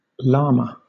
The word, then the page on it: llama: British English